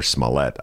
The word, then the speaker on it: Smollett: Smollett, or Smollett, I should say. I've been corrected many times. I've been mispronouncing the man's name.